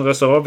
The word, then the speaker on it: Restaurant: Jankel, ich spreche Französisch, im Restaurant wird am Ende wohl kaum mit einem G ausgesprochen, im Französischen fällt der letzte Buchstabe weg, deshalb Aussprache Restau, Ran.